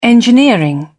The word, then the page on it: engineering: British English